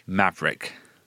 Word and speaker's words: Maverick: Maverick.